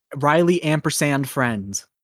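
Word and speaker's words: Riley & Friends: You really you pronounce the nd there. It's, it's Anne with the Anne symbol. Riley and Friends. And Riley ampersand Friends.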